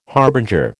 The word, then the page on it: harbinger: American English